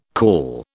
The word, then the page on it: caul: British English